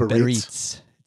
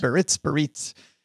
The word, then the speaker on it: Biarritz: It's Biarritz. – So yes. That's probably two different places. – Biarritz.